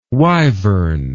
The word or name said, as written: wivern